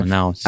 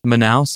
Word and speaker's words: Manaus: And there's also just a ton of insanely violent prison fights going on in facilities in these Amazon states of Brazil, Manaus, which again, I'm probably mispronouncing. – Manaus. – At the PCC. Yeah. Say it again. – Manaus, I think that's the one that's Manaus.